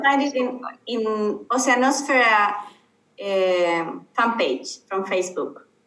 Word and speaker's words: Oceanosfera: And Oceanosfera is spelled ocean and then an O-S-F-E-R-A. Oceanosfera is how you say it in English.